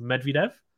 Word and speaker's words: Medvedev: It's like someone said to me uh, when I was doing uh, the final for the Australian Open that Medvedev is actually supposed to be pronounced Medvedev.